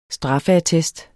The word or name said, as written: straffeattest